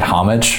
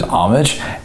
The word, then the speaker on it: Homage: and I had been pronouncing it Homage for a really long time, and they said on the voicemail there was Homage, and it was like, it blew my brain up.